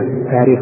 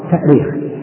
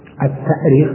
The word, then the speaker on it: التأريخ: أيهما صح في الكتابة والنطق في كلمة التاريخ بالهمزة والتأثير؟ طبعا من حيث الحفظ الاشتقاقي التأريخ، التأريخ بالهمز لأنها أرخ يؤرخ، الهمز أصلي فيه، أرخ يؤرخ تأريخا. وأما التاريخ فهو تسهيل،